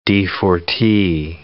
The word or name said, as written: d4T